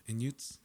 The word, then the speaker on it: Inuits: Oh, Inuits? Is that how you say it? – Yeah.